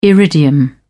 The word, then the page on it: iridium: British English